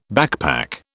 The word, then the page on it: backpack: British English